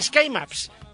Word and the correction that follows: Ski Maps: para saber... Ski Maps sería la forma de pronunciarlo.